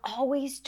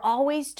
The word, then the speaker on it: always: A quick tip about the word always, you don't have to pronounce the L sound if it's tricky for you. Always, just an open ah sound. Always.